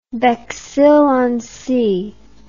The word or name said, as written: Bexhill on Sea